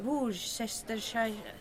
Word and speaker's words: Worcestershire: Du menar Worcestershire... Jag kan inte uttala det. – Precis. Worcestershire-såsen. Worcestershire, säger man.